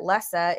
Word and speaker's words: Lisse: The highlight for me was the announcement of Bouquet in Lisse, which is spelled Lisse, L-I-S-S-E, but it's pronounced Lisse, like Melissa.